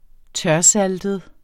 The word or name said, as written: tørsaltet